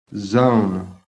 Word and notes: ZONE: American English

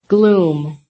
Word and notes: GLOOM: American English

